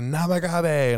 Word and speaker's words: Namagabe: So I called him Name Gabe for, like, the whole first half of the season when we talked about him, and I heard one of the announcers being like, and there's a Namagabe, and I was like, well, I've been saying that wrong.